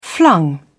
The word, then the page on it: flung: British English